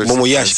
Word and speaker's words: bon voyage: Bon voyage, o sea, no sé cómo se dice, ¿no? Eso bon voyage, como buen viaje.